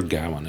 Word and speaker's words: Gawain: Now, I do like that Sir Gawain, or Gawain, if you're. That's how you say it in your head, like I do. But I'm sure Gawain is correct.